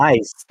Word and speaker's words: Ize: He said, if I pronounce them eyes again, he's leaving the team. It's pronounced I-Z-E. I got to say the letters separately. Yeah, my bad. – I-Z-E. All right. Just call them ice.